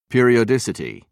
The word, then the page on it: periodicity: British English